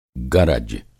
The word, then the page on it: गरज: گرج